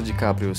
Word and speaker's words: DiCaprio: Você acha que o Leonardo DiCaprio, Eu falei certo? Crápio, não. Você acha que o Leonardo DiCaprio se, se superou nesse filme? – Não, não, tem, tem.